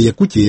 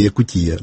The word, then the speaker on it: Якутія: От, і там наголосне Якутія, Якутія, не Якута, Якути. От, повністю освоєно, але це ще було до революції в ті роки.